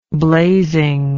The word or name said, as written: blazing